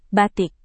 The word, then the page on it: batik: indonesian